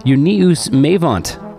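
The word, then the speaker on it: Júníus Meyvant: A band from Reykjavik, Iceland, named Uníus Meyvant. I have it on good authority, that's how you pronounce it.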